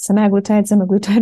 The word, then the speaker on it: semaglutide: I have 50, 60 people on the semaglutide. I never know how to say it. Semaglutide, whatnot.